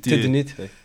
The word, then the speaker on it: tédénite: Ah oui, mais la prononciation, je ne l'ai pas saisie. Ardine, je sais ce que c'est. – Oui, tédénite.